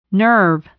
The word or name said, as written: nerve